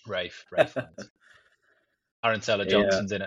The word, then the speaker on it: Ralph: is it Ralph or Rafe? I don't know how he likes to pronounce his name. I call him Ralph. – Rafe.